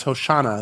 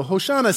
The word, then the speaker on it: Hoshana: It's still its original language, which is Hoshana, is how you say it, which means save me, save me. So Hoshana, save me to the son of David.